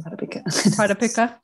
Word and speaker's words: Pradipika: You might be familiar with the text, the Hatha Yoga Pradipika. I always say that wrong. Pradipika.